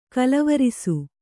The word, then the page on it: ಕೋಲುವರಸೆ: Kannada